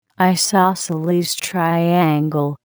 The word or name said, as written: isosceles triangle